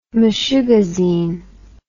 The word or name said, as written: meshugazine